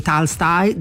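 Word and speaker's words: Tolstoj: Allora, intanto, per far capire che uno sa parlare con la pronuncia, la O in russo diventa A, e quindi Tolstoi, Dostoevskij, eccetera.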